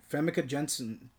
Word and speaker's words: Famke Janssen: Yeah, and this is a year prior for, how do you say her name? – Famke Janssen? – Yeah, Famke Janssen.